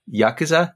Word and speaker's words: Yakuza: Yuck- of Yakuza. Yakuza. I don't get it. Yakuza, Yakuza, but you spell it yuck.